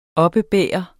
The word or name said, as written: oppebære